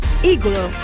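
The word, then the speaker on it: Agloe: que se llama Iglo, Aeglo se escribe, ¿eh?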